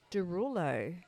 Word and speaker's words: Desrouleaux: It's spelled D E S R O U L E A U X. Derulo. – Yeah, proper. There you go.